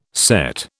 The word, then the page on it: CET: British English